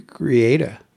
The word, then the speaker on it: Greada: Oh, there's no N in it. It's gre- Greada. Greada.